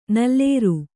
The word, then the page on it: ನಲ್ಲೇರು: kannada